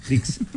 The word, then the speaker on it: Disc: Discs. En Paraguay. – No, espera. D-I-S-C. – Claro, de disco. – Disc. – Disc. – Lo digo bien. – Muy bien, a ver.